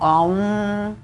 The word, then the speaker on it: Om: El sonido um, es om, que en realidad suena como AUM, y lo vamos a hacer.